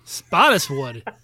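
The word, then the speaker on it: Spottiswoode: Oh, I'm going to get it wrong. Roger Spotswoodle? – Spottiswood. – Spotswood? All right. – Roger Spottiswood.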